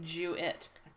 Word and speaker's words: Jewett: So, like, is she saying that right? – I totally am. It's okay. J-E-W-I-T-T. – Jewett.